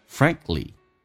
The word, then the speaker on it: frankly: Frankly 首先我们来看一下这个单词指的是坦白的说，相当于 To be frank with you To be frank with you 坦白的说，首先第一个 k 是可以不用发音，因为在美式发音当中啊，那么三个辅音并排中间的辅音可以去掉，你看 k 跟 l 都是辅音，可去掉就行了，就变成 frankly，